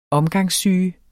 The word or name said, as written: omgangssyge